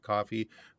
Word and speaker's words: Ko-fi: It's spelled Ko-Fi, but they pronounce it coffee.